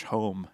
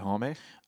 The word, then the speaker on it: Homme: I always pronounce his name Josh Homme. Is it Homme? – I only figured that out recently. It's I don't even Homme? Know if I'm saying it right, but you have to accent the E.